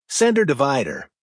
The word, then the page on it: center divider: American English